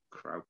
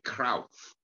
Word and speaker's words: Cruyff: Johan Cruyff, Krauth. It is actually Krauth, if you want to be absolutely, you know, correct in the pronunciation.